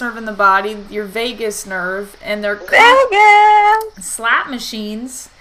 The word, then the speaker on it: vagus: And actually, it's kind of cool that it's called the vagus nerve, even though it's spelled differently. It's spelled V-A-G-U-S. But you can think about it as like your lifeline for this party, right? People party in Vegas all the time.